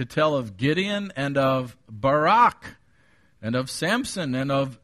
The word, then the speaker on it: Barak: Why are Barak, and by the way, that's how they pronounce it in Israel, the Jewish people, so that's how I'll pronounce it.